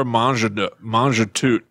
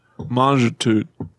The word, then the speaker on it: Mangetout: Monsieur Mangetout. I'm pretty sure you don't pronounce the last T. – Not Monsieur Mangetout. – Bonjour. Bonjour, bonjour. – Oh my God. – Monsieur Mangetout.